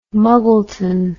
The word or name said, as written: Muggleton